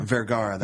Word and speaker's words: Vergara: Vergara. That's how you say it.